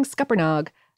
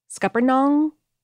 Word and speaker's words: Scuppernong: Also, I'm saying Scuppernog, but I think that is a regional variation on it because there is an N towards the end of that word. It's I think that it's meant to rhyme with song. Scuppernong.